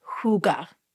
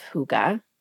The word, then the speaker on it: hygge: What is sparking joy for me as we enter this fall season, smack dab in this fall season, is this concept of hygge. You've heard of this? – Yeah. – Okay. It's spelled H-Y-G-G-E.